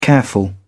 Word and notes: careful: British English

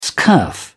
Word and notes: scurf: British English

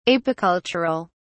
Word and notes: apicultural: British English

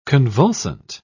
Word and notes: convulsant: British English

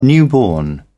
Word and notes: newborn: British English

